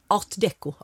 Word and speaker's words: art deco: Det var en saga, ett stort fantastiskt ADK-hus med en ringmur runt, för det har nämligen varit ADK, förlåt. – Art deco. Förlåt att jag snubbade till mig med det franska uttalet.